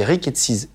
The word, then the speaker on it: rickettsies: Et après, tu te lances à l'hôpital, la Timon dans l'étude des, des rickettsies. C'est comme ça qu'on prononce Oui, c'est comme ça qu'on prononce.